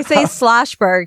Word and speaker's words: Shlosberg: Actually, my friends tell me I pronounce it wrong. So I say Shlosberg because I think it rolls off the tongue easier, but I'm told that it's Shlosberg.